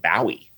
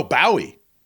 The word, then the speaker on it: Bowie: Let me start by, since it's going to be a whole hour or so, I actually pronounce it Bowie. – Oh, Bowie.